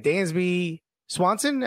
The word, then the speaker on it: Dansby Swanson: Dansby Swanson.